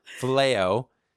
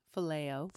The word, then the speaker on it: Phileo: Fiole love? Flamignon love? – Phileo. – And then there is agape. Jesus was saying, do you agape me? Peter goes, what is it again? – Phileo. – Phileo you.